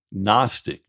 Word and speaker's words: Gnostic: The word breaks up with the prefix a, meaning not, and then the word Gnostic, G-N-O-S-T-I-C, the G is silent, and somehow it's not silent when you throw the A in front of it.